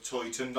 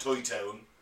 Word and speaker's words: Towton: And this is actually the largest and bloodiest battle fought, and then we saw the Battle of Toyton, not Toytown. – I've been to Toyton, I think. – Yeah, Toyton. It's spelled T-O-W-T-O-N.